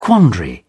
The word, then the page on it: quandary: British English